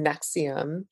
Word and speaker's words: NXIVM: Now I feel like there has been enough put out there about these groups, especially through Nexium, which is spelled N-X-I-V-M.